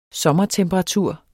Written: Sommertemperatur

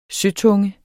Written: søtunge

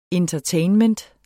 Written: Entertainment

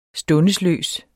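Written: STUNDESLØST

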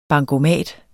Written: bankomat

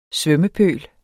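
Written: SVØMMEPØLS

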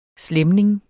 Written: slæmning

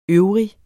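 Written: øvrig

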